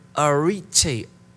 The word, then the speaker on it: arete: Well, let's segue into, let me give you, as we segue into this message tonight, the Greek word excellence is the word arete. I think I'm pronouncing it right. A-R-E-T-E.